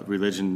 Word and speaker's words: Relijun: So if anyone's looking it up, it's spelled uh, Relijun, not as religion is spelled R E L I J U N.